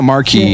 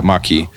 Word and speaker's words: Maquis: Well, it's not Marquee. Yeah, it's not Marquee. It's Brown Curse. Yeah, Maquis.